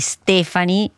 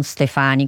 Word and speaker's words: Stefani: Devo ricordarmelo come plurale di Stefano, quindi Stefani e non Stefani, come ho detto prima.